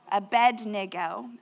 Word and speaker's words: Abednego: I said it right just for him. Abednego. It was my accent if you were here last week, okay? We're just going to chalk it up to that.